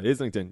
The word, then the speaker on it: Islington: Is that how you pronounce it? Islington? – I think so, yeah. – It's not like Islington or something. No, yeah, Islington, Union Chapel, June 19th.